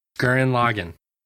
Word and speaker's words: Gurren Lagann: Is it Gundam or Gurren Lagann? Gurren Lagann. – Mm-hmm. – Oh, Grim Lagann. What are you talking about? What's a Grim Lagann? – Gurren Lagann.